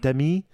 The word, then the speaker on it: Demi: Is it Demi? – It's, yeah. – Whatever. – It's Dummy Moore.